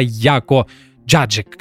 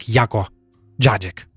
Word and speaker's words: Яко Джаджик: Это польская фамилия, хотя э, сам Яко, будучи британцем, называет себя Яко Джаджик как-то так. Я нашел интервью в, на, точнее, на канале YouTube, где интервьюер шутит по поводу его фамилии, и он сам произносит э, ее как Яко Джаджик.